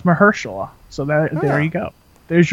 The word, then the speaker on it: Mahershala: It's with, Mahershala Ali, however the hell you say his first name. And that's exactly how you say it.